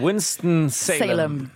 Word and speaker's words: Winston-Salem: Absolut, Winston-Salem.